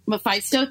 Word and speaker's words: Mephisto: Mephisto, the devil guy? Like, he's the devil? – Well, he... – Mephisto, thank you.